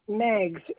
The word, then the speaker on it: Meigs: My name is Sharon Meigs. It's spelled M-E-I-G-S.